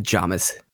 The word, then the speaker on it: jamais: I've always just said Jamais. – Jamais. Yeah, it's French. You leave off the last letter, and that's how you pronounce it.